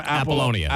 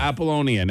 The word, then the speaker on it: Apollonia: Apollonia. – Apollonia.